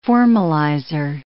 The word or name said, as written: formalizer